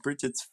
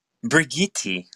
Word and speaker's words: Brigitte: We're going to go ahead and, I don't know if it's Bridget, actually. There's more letters in there than I was expecting. – Good morning. It's pronounced Brigitte. – Brigitte. Is that right? – Yes.